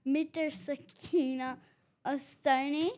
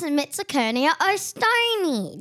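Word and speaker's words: Mitsukurina owstoni: Midrsakina Ostoni? – It's pronounced Midrsakina Ostoni.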